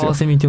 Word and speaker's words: 翡翠: カワセミの漢字表記はこの翡翠って書く。そのまんまで読み表すことができるんですよ。 – カワセミって読むんだね。 – そう、翡翠って書いてカワセミって読むし、カワセミって書いて翡翠って読むんですよ。